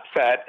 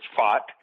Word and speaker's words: Pfaadt: It's not Fat, it's Pfaadt. That's the way it's pronounced.